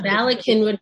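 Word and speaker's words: Bala Cynwyd: She and her husband, Rabbi Avraham Schmidman, are rabbi and Rebbetzin at the Lower Merion Synagogue in Ballast Inwood. Did I get that right? How do you pronounce that? – Kinwood, but don't worry, it's well. – Kinwood, Valley Kinwood, Pennsylvania, sorry.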